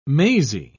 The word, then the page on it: mazy: British English